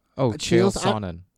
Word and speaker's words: Chael Sonnen: Chael Sonnen, S-O-N-N-E-N. – Chael Sonnen.